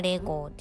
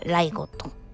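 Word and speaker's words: LEGO: いにライゴットと読ませる読むんですけどこのライゴットが語源となってレそのまま単語を社名にしてレーゴあ日本語読みだったらレーゴですけどデンマーク語はライゴットと発音するようですね。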